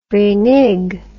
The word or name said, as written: renegue